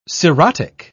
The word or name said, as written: cirrhotic